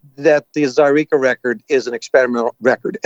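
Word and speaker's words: Zaireeka: You're coming off of Zyreka.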